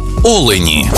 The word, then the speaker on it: олені: однині і в множині наголос падає на перший склад – олень та олені.